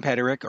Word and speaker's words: Peterik: The UK in me wants to say Peterik, but the proper pronunciation. – Everybody from England says Peterik, and I go, it's Peterik.